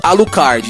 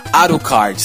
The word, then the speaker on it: Alucard: E, tipo, em inglês é Alucard. Em japonês, né? Como os japoneses não conseguem pronunciar, né? Por se tratar da linguagem deles, né? Se pronuncia Arucard.